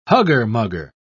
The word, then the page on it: hugger-mugger: British English